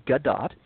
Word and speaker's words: Gadot: That is actually how you're supposed to pronounce her name, Gadot,